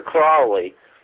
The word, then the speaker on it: Crowley: Well, Crowley, to rhyme with holy, that is, supposedly the way that he pronounced it and the way that the people in various magical orders that follow his teachings swear by the pronunciation of Crowley.